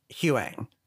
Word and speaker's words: Huyang: Because, like, it's spelled Huyang, but Ahsoka pronounces it Huyang.